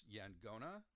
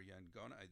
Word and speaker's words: yaqona: Uh, so this word Y A Q O N A, which in the local language is actually pronounced Yangona or Yangona. That's again, I don't know if there's any particular emphases on, on how you do this, but um, instead of a Q sound, it's a G sound.